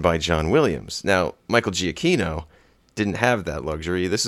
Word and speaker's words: Giacchino: Giacchino, yeah.